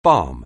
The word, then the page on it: balm: anglais